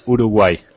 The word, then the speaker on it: Uruguay: Now, what is the correct way to pronounce it? As a kid, I used to learn Uruguay, but when I hear people say it, it's more Uruguay. – Exactly. In Spanish, it's Uruguay. República Oriental del Uruguay.